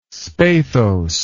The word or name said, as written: spathose